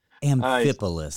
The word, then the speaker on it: Amphipolis: Apollos means city. And so usually you'll pronounce the last part, Paulus. And so Amphipolis or Amphipolis doesn't matter. – Amphipolis.